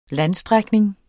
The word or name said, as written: landstrækning